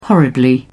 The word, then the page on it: horribly: British English